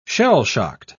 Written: shell-shocked

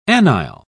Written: anile

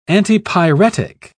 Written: antipyretic

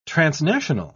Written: transnational

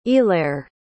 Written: eallair

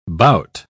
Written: bout

